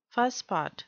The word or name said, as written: fusspot